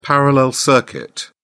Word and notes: parallel circuit: British English

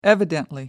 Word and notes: evidently: American English

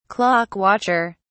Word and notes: clock watcher: British English

